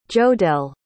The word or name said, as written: jodell